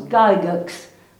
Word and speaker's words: Gygax: Le fondateur de la chose en question s'appelait, ça se prononce Gygax, mais en fait, on va l'appeler Gygax, hein, G-Y-G-A-X, et il dit ceci, Gygax.